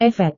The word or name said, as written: efek